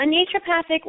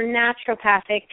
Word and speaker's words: naturopathic: So a naturopathic or naturopathic, people pronounce it both ways, doctor, is a healthcare professional who's trained in both conventional primary care medicine and alternative medicine, as well as naturopathic medical philosophy.